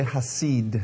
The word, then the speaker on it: hesed: Everyone say hasid. Hasid, hasid is spelled H E S E D.